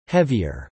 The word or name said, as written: heavier